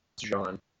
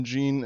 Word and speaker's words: Jean: It is actually technically supposed to be pronounced Jean. – Huh. – It was one of those things where it was like he like people started calling him Gene, and he was like, all right, like I'm not going to correct people.